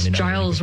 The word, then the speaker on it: Giles: And it's Giles with a G for those of us who pronounce, give correctly. It might confuse a little.